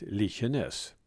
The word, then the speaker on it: Likenäs: Det dialektala uttalet har varit Likenäs.